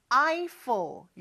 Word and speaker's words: Eiffel: 자, 영어로는 조금 발음이 다르죠. 에펠이 아니고 Eiffel. 이렇게 발음을 해요.